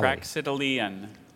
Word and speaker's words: Praxitelean: Praxitelean.